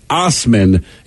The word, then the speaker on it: Assman: And in spite of the fact that his last name is pronounced Osman, it's actually spelled Assman, A-S-S-M-A-N.